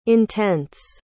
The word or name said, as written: intents